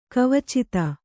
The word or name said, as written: ಕವಚಿತ